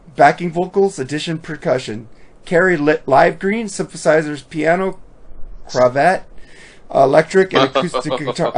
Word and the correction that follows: Clavinet: I said it wrong. How do you say it? Clavinet.